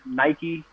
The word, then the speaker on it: Nike: I believe Nike is actually how you say his name. Let me see if I can find it here. Yeah, Nike Sabandi, really solid scorer.